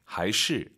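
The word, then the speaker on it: 还是: 이게 재밌는 게 하이시 아닙니다. 경성이에요, 시가.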